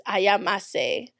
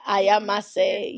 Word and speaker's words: Ayamase: Or I think how they would pronounce it is ayamase. Yeah, ayamase. Yes.